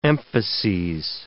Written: emphases

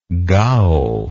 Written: Gao

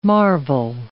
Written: marvel

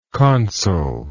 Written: consulship

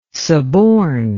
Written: suborn

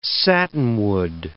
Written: satinwood

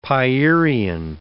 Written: Pierian

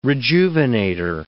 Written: rejuvenator

